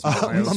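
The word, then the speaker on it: Mikkola: It's funny that you bring up, is it Mikulas? Is it Mikulas? Is that how you say it? Nico Mikulas? Something like that? – Mikula. – Mikula, yeah. What did I call him the other day?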